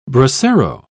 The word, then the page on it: bracero: British English